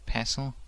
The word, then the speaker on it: pestle: And so he started making, eventually started making his own chocolate with a mortar and, how do you say pestle? – It? Pestle. I don't know if you'd pronounce the T or not. Pestle. – I would actually, but that's probably just because I read it.